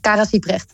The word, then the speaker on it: Kara Siebrecht: En Kara Siebrecht, zeg ik dat goed? – Ja, Kara Siebrecht.